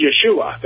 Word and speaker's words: Yeshua: it's Yeshua. That's how you say it in Hebrew.